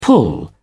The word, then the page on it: pull together: British English